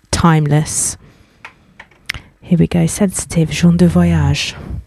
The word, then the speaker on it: Jean du Voyage: So we're going to open up with Sensitive by French producer Jean du Voyage, pronounced Jean du Voyage, my best French accent.